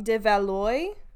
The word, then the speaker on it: de Valois: So he married Charlotte, and I'm going to say this wrong, de Valois in... – Sounds right to me.